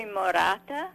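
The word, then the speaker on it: Morata: Is it Moretta? Am I pronouncing that correctly? – It's actually Moretta.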